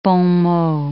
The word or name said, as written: bon mot